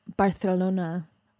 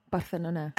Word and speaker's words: Barcelona: because like I've never been to Barcelona. It sounds romantic as hell. – I've heard it's the most fun, most romantic city in the whole world. – It's pronounced Barcelona. Barcelona.